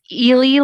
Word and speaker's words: Ely: It's Ely Log Cabin. So it's spelled E-L-Y.